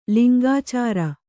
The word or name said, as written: ಲಿಂಗಾಚಾರ